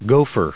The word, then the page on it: gopher: go*pher; American English